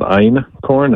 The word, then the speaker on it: einkorn: I mean, the major one is called einkorn. It's spelled E-I-N and then K-O-R-N, like ein and then corn.